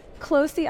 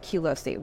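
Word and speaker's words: Qlosi: Closey actually makes so much more sense than Q-Losey, what I was saying.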